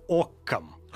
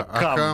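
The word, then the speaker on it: Ockham: Речь об э, английском монахе, францисканцем он был, по-моему, звали его Уильям Окком. Да, мы привыкли а, Акам, ну, да, потому, что нас, потому что нас так учили.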